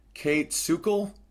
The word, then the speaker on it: Kayt Sukel: And the other one is called This Is Your Brain on Sex by, let me pronounce this properly, Kate Sukel. K-A-Y-T-S-U-K-E-L.